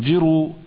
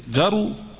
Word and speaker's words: جرو: قال: ثم التفت فإذا جروي كلب، أو جرو كلب، هي تنطق بالكسر للجيم وبالفتح كذلك، جرو وجرو، والجرو هو صغير الكلاب والسباع عموما، الجرو هو صغير الكلاب والسباع عموما فيسمى جرو، أو جر، نعم، وجمعها جراء.